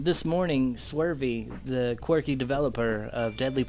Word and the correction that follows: Swery: I, I always called him Swery. Oh, well, it probably is Swery. What I say, Swervy?